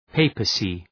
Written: papacy

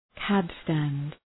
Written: cab-stand

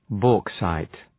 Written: bauxite

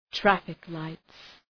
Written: traffic lights

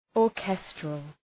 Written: orchestral

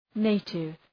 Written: native

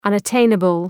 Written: unattainable

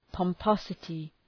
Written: pomposity

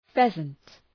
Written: pheasant

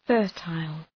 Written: fertile

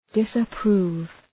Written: disapprove

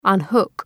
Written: unhook

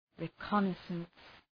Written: reconnaissance

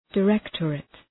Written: directorate

